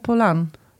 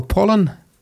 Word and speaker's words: Pollan: Spreek je het zo uit, Pollan? – Michael Pollan. – Oh, ik dacht Michael Pollan.